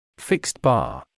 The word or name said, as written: fixed bar